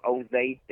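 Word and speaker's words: Osaze: I am Oze, that's spelled IAMOSAZE.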